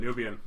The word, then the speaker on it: Nubian: But yeah, and that's actually how it's said, Nubian.